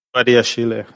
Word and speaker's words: Badiashile: Badiashil, is that how you say it? – Badiashile, I think. – Yeah, Badiashile. Thank you.